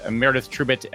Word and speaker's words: Meredith Trubitt: So I'm pleased to introduce Meredith Trubit and Moni Amin.